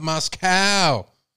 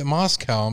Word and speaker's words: Moscow: Hi, I love your show, but please say Moscow correctly. It's Moscow. Long O, not Moscow. We're not in Russia. I'm from Idaho. It drives me crazy. Thank you. I'm from Texas. We say it Moscow.